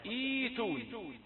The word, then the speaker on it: ائتوني: إذا نطقنا الهمزة الأولى لا ننطق الثانية مظهرة، ولكن نبدلها مدا من جنس الكسرة التي قبلها، إيتون.